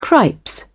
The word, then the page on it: cripes: British English